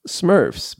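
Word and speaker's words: SMIRFS: Smurfs, pronounced Smurfs, but it's S-M-I-R-F-S, standing for SModCo Minor Internet Radio Fans.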